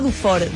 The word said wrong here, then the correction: Dufour: Dufar. Maru Dufar. María Eugenia Dufar. ¿Sabes, Charlie? Seibane, ¿cómo te va? – No, perfecto. Yo lo pronuncio muy bien a tu apellido. – A ver. – Maru Dufar.